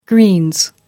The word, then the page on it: greens: British English